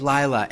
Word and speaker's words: Lila: I told you my name was Lila, L-I-L-A, and you told me you had a cousin who pronounced it the same way but spelled it differently, L-E-I-L-A.